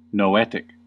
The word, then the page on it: noetic: American English